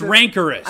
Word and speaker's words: rancorous: Yeah, you totally pronounced that wrong. Rancurious. – Wait, hold on. No, it's rancorous.